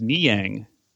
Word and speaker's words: Niang: On basketball reference, it says his last name is pronounced Niang.